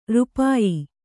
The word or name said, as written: ರೂಪಾಯಿ